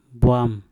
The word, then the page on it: bwam: Mpur